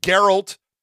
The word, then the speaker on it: Geralt: We've had Geralt, that's how you pronounce it, of Rivia, on the show before taking down the Winter Soldier.